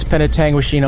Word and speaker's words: Penetanguishene: Well, it's Penetanguishene, Ontario, on the shores of Georgian Bay, Ontario.